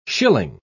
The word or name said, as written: shilling